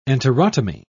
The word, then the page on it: enterotomy: British English